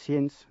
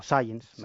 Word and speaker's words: Science: Esta es una historia que además ha dado a conocer una de las revistas científicas más importantes, Science, ¿no? Y, o Science, mejor dicho, que luego sí, se meten conmigo por mi pronunciación.